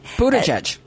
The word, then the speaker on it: Buttigieg: Biden Transportation Secretary Pete Buttigieg took Smollett's side. Did I say that right? – Buttigieg. – Thank you.